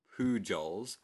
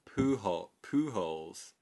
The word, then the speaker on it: Pujols: But when you read how to pronounce it, I thought it was Albert Pujols. – Yeah. – But if you pronounce it properly in like with a Spanish accent, go on. – It's actually called Pujols.